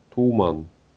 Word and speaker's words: туман: Да, да, только, только с поправкой на ударение. А как? Ну, через У. Туман. Больше через... на У. – Просто, просто на будущее знаешь ударение на У.